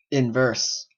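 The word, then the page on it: inverse: Canadian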